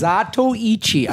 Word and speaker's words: Zatoichi: Sounds like you're saying Zatui Cheese. – No, white guy. Sandy in East Korea knows what I'm talking about. – We're about to get an email. Here's how you pronounce it. – It's Zatoichi. I know I'm pronouncing that correctly.